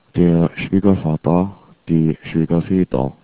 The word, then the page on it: schwiegervater: german